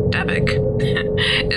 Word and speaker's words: Dybbuk: The story of the Dybbuk, pronounced Dybbuk, is rooted in centuries-old Jewish folklore.